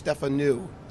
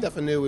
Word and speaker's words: Stefanou: The easiest way to do it is Stefanu. So new as in something that's new as opposed to old. – Uh-huh. – So Stefanu is the way I pronounce it.